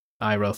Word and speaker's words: Iroh: Iroh. – Iroh, thank you.